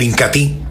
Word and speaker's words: Incatea: Y hablando de otro de los productos líderes de la aldea Ramazón, sin lugar a dudas, mis amigos, encontramos el Incatí. Se escribe Incatea, pero se pronuncia Incatí.